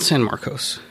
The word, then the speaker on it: San Marcos: You changed the pronunciation on that. – That's how I've always said it. – No, it's not San Marcos. – There's no U in it.